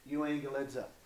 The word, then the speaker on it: euangelizo: If I was to give you, like if you're looking in to break the word down, you're going to have it like this in the pronunciation, euangelizo. This is probably how you would see it written. This is how you would pronounce it.